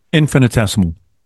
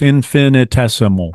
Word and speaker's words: infinitesimal: Infinitesimal. – Infinitesimal, isn't it? – You're so close. Infinitesimal. – I'm missing a syllable.